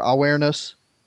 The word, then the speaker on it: Avernus: at the site of Mount Avernus uh, or Awareness, if you're going to pronounce it like a Virgilian, like you probably should, uh, to descend into the underworld as Virgil imagines it.